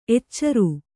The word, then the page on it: ಎಚ್ಚರು: eccaru